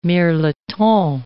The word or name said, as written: mirliton